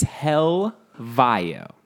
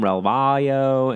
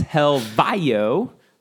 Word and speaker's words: Helvayo: It's Helvayo. That's how you say it. I know a lot of people, ASN Network, they call him Relvayo. I mean, to be fair, I mean, that's phonetically, it reads Relvayo. – Rodeo Drive? – That's Rodeo. But either way, he's from Portugal. It's pronounced Helvayo.